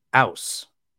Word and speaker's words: Aass: And the other one is spelled AASS, Now, they insist that this is pronounced ouse. I'm not familiar with the Norwegian language. If anybody is, you know, please enlighten me. But, you know, if I were to just read that word, I would pronounce it differently.